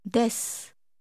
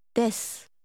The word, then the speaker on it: です: です。す。です。です。 – Notice how the ending sound fades away. – す。 – How do you say are? – です。です。 – Say you are. – あなたは。です。あなたはです。 – Now try to say, you are American. Literally, you American are. – あなたはアメリカ人です。